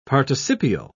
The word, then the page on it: participial: British English